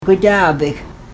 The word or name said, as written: gwadaabik